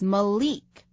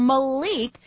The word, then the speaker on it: Malik: Yes, M-A-L-I-K. Often these days with names of people that are from the black culture, the I is pronounced like an E. So this name is Malik, not Malik.